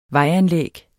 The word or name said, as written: vejanlæg